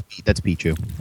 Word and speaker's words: Pichu: Yeah, that's Pichu.